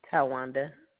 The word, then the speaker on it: Tywanda: Tawanda. – Please spell it. – T-Y-W-A-N-D-A. – Hi, girls. – Okay, T-Y-W-A-N-D-A.